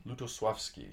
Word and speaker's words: Lutosławski: Is that the right pronunciation? – Lutosławski, yes. We learned this at Cornell.